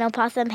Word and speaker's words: opossum: I've been starting to eat like an opossum, hanging upside down on the monkey bars. – Okay, she hasn't grown a tail yet, but she's pretty obsessed. And I get it. Opossum rhymes with awesome, so bonus points there.